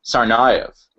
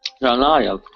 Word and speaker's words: Tsarnaev: This is how you pronounce Tsarnaev. I love that there's a whole YouTube video by pronunciation guy just telling you how to pronounce the surname of a convicted terrorist who... Yeah, that's odd. Here's how you pronounce Sarnayev.